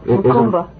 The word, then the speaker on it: Macumba: Did I pronounce that right? – That's right. Macumba. – Macumba, just Macumba, yeah.